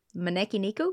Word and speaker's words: Maneki Neko: In Japan, the famous image of the beckoning cat, which is Maneki Neko, if I'm saying that right? – Yeah.